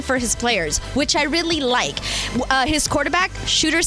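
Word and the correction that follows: Shedeur: Sanders, did I say that right? – Shador.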